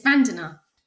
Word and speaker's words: Vandana: Have I pronounced that correctly, Vandana? – Hi, Phil. Yes, it's Vandana.